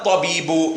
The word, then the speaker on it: الطبيب: So we're saying هذا and then الطبيب. We pronounce it هذا الطبيب من الهندي.